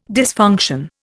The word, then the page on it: dysfunction: Indian